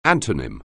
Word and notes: antonym: anglais

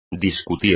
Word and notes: discutir: espagnol

